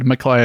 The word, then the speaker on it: McLeay: It's one of those names where you just got to, like, in my head, McClay is pronounced McClay, M-C-L-E-A-Y.